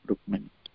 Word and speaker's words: Rukmini: Did I pronounce Rukmini Devi? I mean, that name correctly? Can you please correct me if I'm wrong? – Oh, that is correct, Mataji, Rukmini.